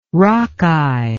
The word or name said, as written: Rockeye